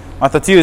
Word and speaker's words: mathiteo: Mathateo is spelled M-A-T-H-I-T-E-O. Mathateo. I'll spell it again. M-A-T-H-I-T-E-O.